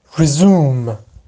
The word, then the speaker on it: resume: در معنای فعلیش resume یا resume تلفظ میشه این لغت، در معنای اسمیش که همون خود رزومه خودمونه تلفظ اسمیش میشه رزومه رزومه رزومه. جز لغتایی که تلفظ اسم و فعلش با هم فرق داره. پس فعلش شد رزوم و رزیوم، اما اسمش میشه رزومه رزومه.